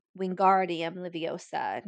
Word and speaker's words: Wingardium Leviosa: And something else that they, slight change, in the book, the pronunciation is the gar in Wingardium Leviosa, not the O in Leviosa. And with the movies, the Leviosa has been it's like an iconic line now, mm-hmm.